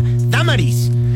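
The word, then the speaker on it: Dámaris: O sea, como Damaris, pero acento en la apóstrofe en la primera A, Damaris.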